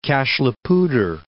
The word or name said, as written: Cache la Poudre